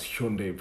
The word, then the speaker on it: Hyundai: You know, I really like that you pronounce Hyundai properly.